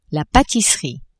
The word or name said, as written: patisserie